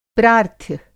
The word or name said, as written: प्रार्थ्य